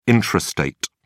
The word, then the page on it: intrastate: British English